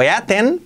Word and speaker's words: eaten: Escrito eaten, pero pronunciado eaten.